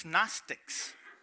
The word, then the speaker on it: Gnostics: If you're like me, you think, how do they get Gnostics? It starts with a G. Being from Kentucky, I had a hard time figuring out how to spell that word, but it's Gnostics.